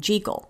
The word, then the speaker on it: Jekyll: Anyway, that's why I'm saying Jekyll.